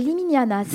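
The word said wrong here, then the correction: Limiñanas: Liminianas C'est Ah, les interviewer, il faut déjà bien le prononcer.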